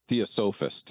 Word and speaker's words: theosophist: Charles Williams was the theophysite. Is that how you say it? – Theosophist. – Theosophist, thank you.